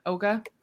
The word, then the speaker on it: Oga: Oga, right? Did I say it right? – Yeah.